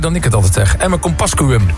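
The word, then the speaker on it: Emmer-Compascuum: Emme Kompascuum, zeggen wij altijd.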